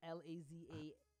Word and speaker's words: lasagna: I disagree with both of fun, but say, could y'all please stop saying lasagna? Lazanya. – How you say it? – That's exactly how you say it.